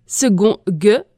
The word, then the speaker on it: second: Second se prononce second gue avec le son gue et s'écrit avec un C et on dit seconde et secondaire.